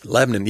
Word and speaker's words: Lebanon: Lebanon. Yes, that's how they pronounce it. Just like in Tennessee. – Just like in Tennessee. Okay. Lebanon.